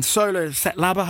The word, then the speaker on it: Ntsolo Setlaba: It's myself and Ntsolo, Setlaba.